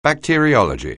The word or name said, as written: bacteriology